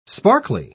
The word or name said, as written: sparkly